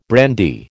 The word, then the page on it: brandy: American English